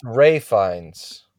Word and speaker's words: Ralph Fiennes: It's with Ralph Fiennes, starring Ralph Fiennes and, of course, Anya Taylor-Joy, even having... – It's pronounced Ray Fiennes.